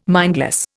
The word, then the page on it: mindless: Indian